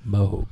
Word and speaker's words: Moog: It's pronounced Moog. – Moog.